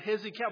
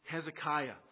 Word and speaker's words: Hezekiah: It was, I don't know if we were hearing the pronunciation wrong or if my grandfather had heard it wrong. But it was actually the name Hezekiah. And so despite the mispronunciation or the misunderstanding, whichever it was, Hezekiah actually is a good choice for a name if you're going to name something because of its strength.